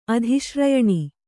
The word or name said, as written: ಅಧಿಶ್ರಯಣಿ